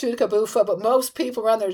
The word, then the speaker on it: Tchoutacabouffa: T-C-H-O-U-T-A-C-A-B-O-U-F-F-A. It looks like Chutakabufa. – Oh my God. – Wow. You got it.